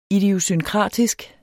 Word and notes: idiosynkratisk: idiosynˈkʁɑˀdisg; ˈidiosynˌkʁɑˀdisg

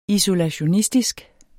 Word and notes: isolationistisk: isolaɕoˈnisdisg

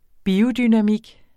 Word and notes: biodynamik: [ ˈbiːo- ]

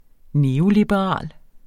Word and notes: neoliberal: ˈneːolibəˌʁɑˀl

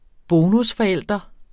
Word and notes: bonusforælder: -fʌˌεlˀdʌ; -fʌˌεlˀʌ

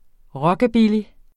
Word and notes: rockabilly: [ ˈʁʌgabili ]; [ ˈɹʌgabili ]

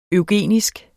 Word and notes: eugenisk: œwˈgeˀnisg

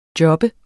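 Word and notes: job: Danish

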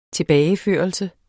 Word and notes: tilbageførelse: -ˌføˀʌlsə

